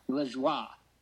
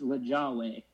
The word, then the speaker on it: Lajoie: I don't think that's how you pronounce it. I pronounce it Lajoie. but they say it's pronounced Lajawe. And so he was actually... It's spelled like Lajoie in French. L-A-J-O-I-E.